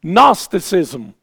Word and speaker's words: Gnosticism: Gnosticism starts with a G that you don't pronounce. I don't know why.